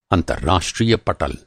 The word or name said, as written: पटल